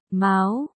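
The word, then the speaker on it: máo (苗): Ở đây chữ miêu trong miêu miêu, nhũ danh của Diệp Chi Hòa, là meo nghĩa là lúa non, mầm, phát âm là, máo, đồng âm với chữ mao nghĩa là con mèo.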